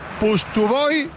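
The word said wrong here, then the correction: Pustovyi: Yo lo he escuchado en el botoncito de la CB con su nombre, que te dice cómo se pronuncia. Y él mismo dice Pustovi. Vale. Claro, Pustovi.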